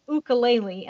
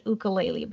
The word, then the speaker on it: ukulele: I did pick up the ukulele and I feel such a weirdo when I pronounce it ukulele, but that is the way it's supposed to be pronounced.